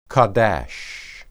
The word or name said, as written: qadash